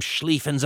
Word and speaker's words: Schlieffen: For deviating from Schlieffen's amazingly brilliant plan, is the way it's pronounced.